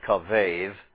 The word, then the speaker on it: kavav: This word, kavav, also variously pronounced kavev, this word means to be heavy.